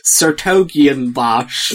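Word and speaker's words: 's-Hertogenbosch: Oh, no, sorry. It is *-Hertogenbosch. It's pronounced Sertogenbosch.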